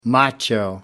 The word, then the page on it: macho: American English